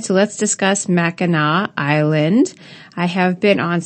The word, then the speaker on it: Mackinac: It's pronounced Mackinac, but spelled Mackinac.